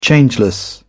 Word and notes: changeless: British English

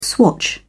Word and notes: swatch: British English

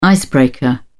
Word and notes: icebreaker: British English

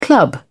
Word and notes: club: British English

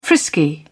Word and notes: frisky: British English

